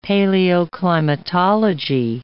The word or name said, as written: paleoclimatology